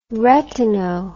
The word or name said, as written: retino